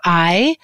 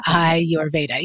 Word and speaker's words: Ayurveda: I had never heard the word Ayurveda, which a lot of people don't even know how to say it. So I like to always share Ayurveda. So Ayurveda, yep.